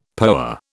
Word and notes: POA: British English

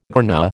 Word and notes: tRNA: American English

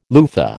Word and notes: loofah: American English

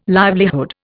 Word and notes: livelihood: Indian

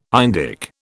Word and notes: Indic: British English

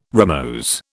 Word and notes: rimose: British English

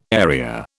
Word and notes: area: British English

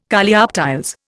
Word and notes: Coleoptiles: Indian

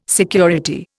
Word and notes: security: Indian

